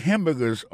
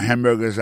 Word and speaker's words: hamburger: Pour ça concerne les prononciations, ou après hamburgers à son mot qui est trois syllabes, je dis hamburger, hamburger, mon manger. Bon, tu manges, moi, hamburger à mort. Tu y es, on achète, bam, tu hamburger. Si tout le monde a un petit cheese ou un petit fromage, du bon en bouche en pile, it's very tasty. N'a pas remarqué que dans mon hamburger, ça a comme t'as dit, il y a trois syllabes là-dedans. Côté appui et puis plus ça, c'est sous premier syllabe là.